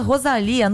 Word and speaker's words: Rosalía: É onde está a artista catalã Rosalía, no Hype, que se apresenta hoje e amanhã na Farmácia Arena, no Rio de Janeiro.